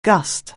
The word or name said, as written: gust